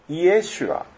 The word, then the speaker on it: イエシュア: このイエスという名前ですが、これはギリシャ語読みなんですね。ヘブル語の発音だとイエシュアって言います。